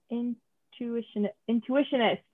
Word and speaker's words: Intuitionist: Oh, wait, am I pronouncing that wrong? Intuitionist.